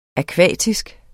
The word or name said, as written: akvatisk